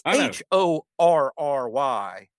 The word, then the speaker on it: Horry: But I think it's pronounced Ori. But I mean, it's an H right in the. I mean, when it's H O R R Y, it's fair to say Horry, regardless of how the guy says it.